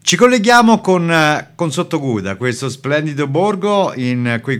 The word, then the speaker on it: Sottoguda: Allora, il nome è giustissimo, appunto dai piedi della Marmolada a Sottoguda.